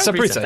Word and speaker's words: Saprissa: And Deportiva Saprissa. Is it Saprissa? Is that how it goes? – Yeah, Saprissa.